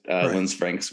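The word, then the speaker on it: Lisfranc: We know he's, you know, he's got his Liz Frank fracture.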